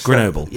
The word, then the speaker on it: Grenoble: is it Grenoble? – Yes. – Grenoble.